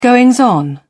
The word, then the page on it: goings on: British English